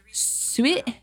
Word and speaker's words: suet: Suet? Is that how you say it? – No. Suet.